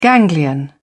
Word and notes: ganglion: British English

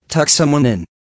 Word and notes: tuck someone in: American English

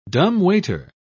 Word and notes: dumbwaiter: British English

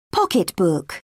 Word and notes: pocketbook: British English

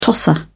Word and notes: tosser: British English

